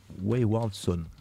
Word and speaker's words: Wayward Son: Et je vois ce groupe qui s'appelle, oh putain, j'ai mal le prononcer, Wayward Son, c'est ça le tout? Wayward Son.